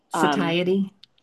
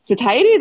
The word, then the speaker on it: satiety: Satiety. – Satiety, that's how you say it.